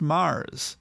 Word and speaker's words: Moers: The company gave a trip to him and his wife to Mars, Germany. It's spelled M-O-E-R-S, but it's pronounced Mars.